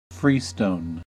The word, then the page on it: freestone: American English